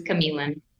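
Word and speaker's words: Q'emiln: For a beach, it's spelled with a Q, so it always gets pronounced wrong, but it is Chameleon.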